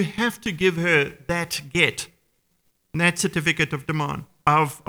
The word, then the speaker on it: get: That certificate was called a get, G-E-T, that's how you pronounce it.